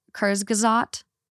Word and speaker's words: Kurzgesagt: Um, it's German, and I will butcher the name, but it's it's pronounced like Kurzgesagt.